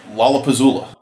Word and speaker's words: Lollapuzzoola: You have to learn how to say it. – How do I say it? – Lollapuzzoola. – Wait, that's Lollapalooza. – Oh. – Lollapuzzoola. – It's like a spoonerism. – It is sort of like it's an internal spoonerism.